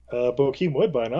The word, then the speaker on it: Bokeem Woodbine: Bookum Woodbine, who was straightjacking, or China Chow? – Bokeem Woodbine, obviously. – I totally butchered that name.